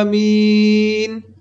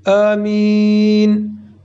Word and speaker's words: آمین: نحوه تلفظ آمین به دو شکل صحیحه، با کشیدن آ و بدون کشیدن آ، آمین و آمین. برخی اون رو با تشدید میم تلفظ میکنن، آمین. این شکل تلفظ صحیح نیست.